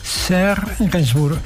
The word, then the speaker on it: Serge Gainsbourg: Serge Gainsbourg, perfecto.